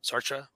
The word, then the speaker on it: Sartre: Sartre? How do you say his name? S-A-R-T. Sartre.